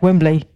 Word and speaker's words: Wembley: You have to say it like Wembley. You can't say Wembley Stadium. It's Wembley.